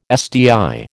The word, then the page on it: SDI: American English